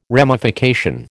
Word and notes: ramification: American English

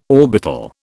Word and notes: orbital: British English

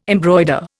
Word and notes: embroider: Indian

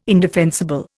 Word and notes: indefensible: Indian

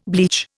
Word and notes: bleach: Indian

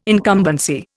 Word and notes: incumbency: Indian

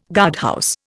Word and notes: guardhouse: Indian

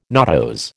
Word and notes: nodose: American English

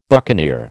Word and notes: buccaneer: American English

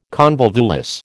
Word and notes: convolvulus: American English

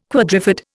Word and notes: quadrifid: Indian

